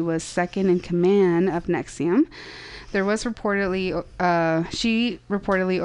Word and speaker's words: NXIVM: His group, Nexium, it's spelled N-X-I-V-M, presented itself as a female empowerment organization.